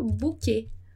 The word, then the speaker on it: bouquet: Muito bem, então como eu já falei, né, o S no final de palavra a gente não vai pronunciar e nem o T. Então a gente vai falar bouquet. Bouquet, como em português.